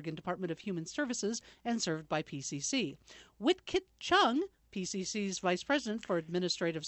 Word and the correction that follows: Wingkit Chung: And I did mispronounce that. It's actually Wingkit Chung.